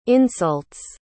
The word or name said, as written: insults